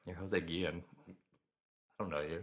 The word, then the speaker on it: Guillen: Jose Guillen, I'm probably mispronouncing that. – I think it's probably Guillen. – Guillen. – Yeah.